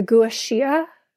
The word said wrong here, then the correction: gua sha: Gua shia? How do you say that? – Gua sha.